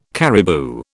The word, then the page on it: caribou: British English